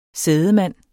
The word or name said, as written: sædemand